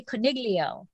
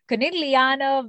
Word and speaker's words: Conegliano: And I put that hard G in there. I say Coniglio, Coniglia. And so that's wrong. Conegliano. I'm sure it's not supposed to have a hard G. So Conegliano Valdobbiadene Superiore DOCG.